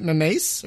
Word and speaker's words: Mamais: Is it Mamayas? – Mamayas.